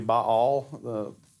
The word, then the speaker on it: Baal: We've always said Baal, and I just the word there in Hebrew is probably Baal, is what that means. So if it sounds weird to you, like, what, is that an Arkansas thing? No, that's just I think that's the correct pronunciation.